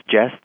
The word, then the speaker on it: Jess: My name's Jess. They say, sure. Spell that. I say, J-E-S-S. S like Sam. And they say, great. Thank you, Jeff.